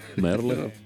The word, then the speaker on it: Merle: No, Working Man Sing Merle, si leggerà Merle.